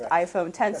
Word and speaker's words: iPhone X: It's iPhone X, but pronounced iPhone X.